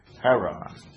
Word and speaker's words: Harran: That was the name of the place, Haran, H-A-R-R-A-N.